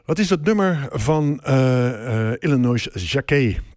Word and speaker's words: Illinois Jacquet: Tenminste, zo spreek ik het uit. De Amerikanen zullen het waarschijnlijk uitspreken als Illinois Jacquet. Maar goed, gezien zijn achtergrond hou ik het maar gewoon bij Jean Illinois Jacquet.